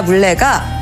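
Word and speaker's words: 문래: 이후 문익점의 손자 물레가 실 뽑는 기계인 물레를 만들었다고 합니다. – 문익점의 손자 이름이 물레였어요? 그 이름이 좀 이상하네요. 그 문물레? – 그게 아니고요. 이름이 문레인데 발음이 물레 이렇게 들리잖아요.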